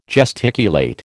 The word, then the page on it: gesticulate: American English